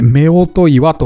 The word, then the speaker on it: 夫婦岩: えー、夫婦岩と書いて夫婦岩と読む、あのー、有名な岩が三重県の伊勢神宮の近くの二見神社の方にありますけれども、まあ、近くもねえかなちょっと離れてるか。はい。あの、夫婦岩、すごく有名ですね。